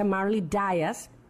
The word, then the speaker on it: Dias: Se trata de Marley Dias, así le dicen, aunque se apellida Díaz, o así se escribe Díaz, como nuestros días, pero eh, ella misma lo pronuncia Marley Díaz, porque ella nació en Filadelfia, Pensilvania, el 3 de enero de 2005 pero es eh, de, ascendencia, perdóname, jamaicana y caboverdiana.